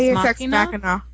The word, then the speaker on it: Deus ex machina: It's the do Ex Machina, but I don't know how to pronounce Deus it. Ex Deus Ex Machina.